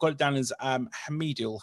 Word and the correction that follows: Heimdal: Have I got that quite, quite right? – Well, uh, it's more like Heimdal.